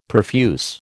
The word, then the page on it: perfuse: American English